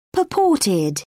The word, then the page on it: purported: British English